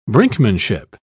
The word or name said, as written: brinkmanship